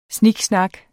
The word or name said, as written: snak